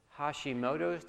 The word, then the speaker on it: Hashimoto's: And they are struggling right now as a family because Julie has just been diagnosed with Hashimoto's disease.